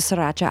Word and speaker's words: sriracha: I get the sriracha out. Sriracha. – Good pronunciation. – Thank you. I can roll my R's.